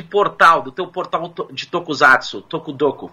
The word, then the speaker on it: Tokusatsu: Tokusatsu, não Tokusatsu. Apesar do S, é uma palavra japonesa, então fala-se Tokusatsu.